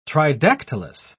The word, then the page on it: tridactylous: British English